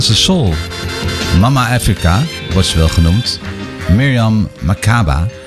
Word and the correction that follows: Makeba: Makeba moet ik zeggen trouwens. En misschien spreek je het nog wel weer anders uit.